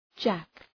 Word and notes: jack: {dʒæk}

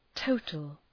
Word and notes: total: ‘təʋtəl

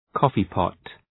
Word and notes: coffee pot: ‘kɔ:fı,pɒt

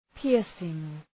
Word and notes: piercing: ‘pıərsıŋ

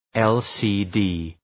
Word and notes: LCD: ,el,sı:’di:

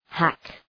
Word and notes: hack: hæk

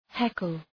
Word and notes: heckle: ‘hekəl

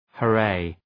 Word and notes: hurray: hə’reı

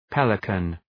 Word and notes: pelican: ‘peləkən

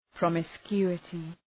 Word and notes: promiscuity: ,prɒmı’skju:ətı

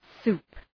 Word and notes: soup: su:p

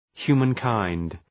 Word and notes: humankind: ‘hju:mən,kaınd

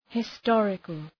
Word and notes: historical: hı’stɔ:rıkəl

